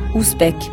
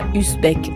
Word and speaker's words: Ouzbek: C'est pour ça que je prononce ton nom comme il faut, Ouzbek, et non pas Uzbek à la française.